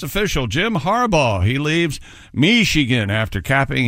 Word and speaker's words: Harbaugh: Jim Harbaugh. – That is the correct pronunciation. – Boy, that is a lot. Yeah, there's a lot of silent letters in that, right? R? – Too many. – Harbaugh.